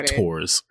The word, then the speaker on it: Thors: They don't pronounce the H like that. They say Tors. Not Thor's.